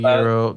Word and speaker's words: gyro: It's a euro. The fucking little flatbread shits with, you know, different types of meats. – Oh, the G, right? – Yeah. – Okay. It's pronounced euro.